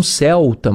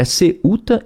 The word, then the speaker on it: Ceuta: Algumas pessoas pronunciam Celta, mas é com U, é Ceuta e Melilla, né?